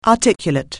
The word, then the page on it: articulate: anglais